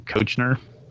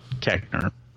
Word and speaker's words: Koechner: Kochner. – Oh, David Koechner? – Koechner.